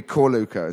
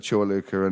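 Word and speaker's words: Čorluka: And it'd always be Corluca instead of Chorluca and Modric.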